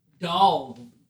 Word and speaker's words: Dawg: It's pronounced Dawg.